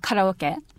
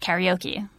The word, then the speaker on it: karaoke: So I will say instead of karaoke, you know, and making it sound so Japanese, you know, I will say karaoke and make it sound a little bit more English. But I think because I learned the word after I was living in Japan for a long mm-hmm, time, before mm-hmm. it became standard in English, right. – It was kind of hard to switch back to that very, I guess, anglicized way of pronouncing it, karaoke, you know.